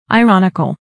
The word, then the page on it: ironical: airɑ́nikəl; アイロニカル